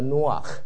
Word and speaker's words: Noach: 他的希伯来的发音叫做 Noach，叫做 Noach。